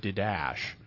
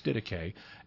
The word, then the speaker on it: Didache: Sometimes people look at the way it's spelled and they think it should be pronounced Didache. – Yeah. – But it's not. It's Didache, and this document is anonymous,